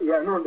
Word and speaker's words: Yannuwn: They say Yannuwn. It's pronounced Yannuwn, dependent on the dialect of what galaxy you're in, the pronunciation changes.